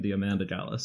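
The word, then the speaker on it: Diamanda Galás: I watched interviews to learn how to pronounce her name, and that's what they were saying, Diamanda Galas. So that's how I'm going to say it.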